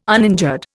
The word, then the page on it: uninjured: Indian